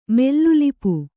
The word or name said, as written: ಮೆಲ್ಲುಲಿಪು